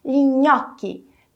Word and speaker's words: gnocchi: Você viu que a pronúncia também não mudou muito de uma língua para outra? Assim como vimos em spaghetti e espaguete? Isso acontece porque o som é realmente o mesmo, só mudou na escrita gnocchi e nhoque.